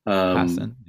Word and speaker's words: Passan: Passan, yeah.